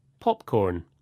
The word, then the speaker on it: popcorn: With compound nouns, the stress usually goes on the first part, like this. Popcorn, ice cream, keyboard.